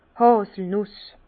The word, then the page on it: d' Hàselnùs: Bas Rhin; Herrlisheim